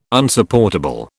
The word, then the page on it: Unsupportable: British English